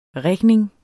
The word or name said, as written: rigning